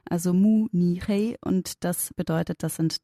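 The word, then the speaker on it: Mu Ni Hei (慕尼黑): Also dass man eben die Silben nennt im Chinesischen, die dem Wort aus dem Ausland besonders nahe kommen, in dem Fall Mu, also Mü gibt es nicht, das Mü von München, aber es gibt Mu, also nimmt man ein Mu und dann nimmt man Zeichen, die zumindest keine schlimme Bedeutung haben, sondern vielleicht was Neutrales.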